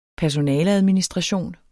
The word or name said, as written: personaleadministration